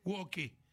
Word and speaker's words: woke: Traté de explicarles hoy muy brevemente qué significa el término woke, que se escribe, es un término en inglés que se escribe W-O-K-E, woke, se pronuncia woke.